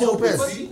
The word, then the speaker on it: Hopez: It's hope with a Z, right? So it's hopes. – That's exactly what it is. – Oh, it's Hopes. – Yeah, it's Hopes. – No, but she actually pronounces it Hopes.